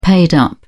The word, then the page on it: paid up: British English